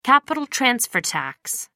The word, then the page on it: capital-transfer tax: American English